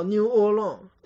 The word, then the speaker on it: New Orleans: 还有就是美国特别著名的一个州的一个城市叫新奥尔良，叫 New Orleans，美国人讲 New Orleans，没有 S 不能发音，包括像伊利诺伊州后面那个 S 也是不能发音。所以法语当中一个特别重要的特点就是最后的一个字母到两个字母它是不发音的。